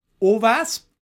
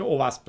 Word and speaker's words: OWASP: اووسپ بده اووسپ همیشه یه مد... O W A S P امیدوارم اوس ترجمه شد من همیشه اوس تلفظش کردم